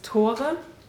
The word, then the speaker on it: Tore: I met this student, his name is Tore, which is spelled like Thor, so it's kind of cool, you know, Thor with an E.